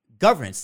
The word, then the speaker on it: governance: Like, what exactly is governance? Is that just government and like? – No, governance. I'm trying to emphasize the second syllable.